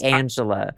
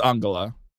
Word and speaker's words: Angela: I think it is Angela. I think you are right, actually. – Angela? Mm-hmm. Okay. Well, there you go. See, I tried to do it the way I tried to pronounce it German.